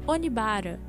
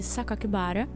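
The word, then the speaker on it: Sakakibara (酒鬼薔薇): Porém, graças a um erro onde divulgaram o nome Onibara no lugar de Sakakibara, já que em japonês a pronúncia dos caracteres poderiam ser identificadas ou como Oni ou como Sakaki, o assassino ficou hiper mega bravo e enviou uma segunda carta ao jornal Kobe Shinbun.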